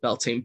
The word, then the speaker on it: Beltane: Uh, obviously, Bel, Beltane being being the anglicized version, but for me, pronouncing it, saying it as Beltan, actually means tan meaning fire,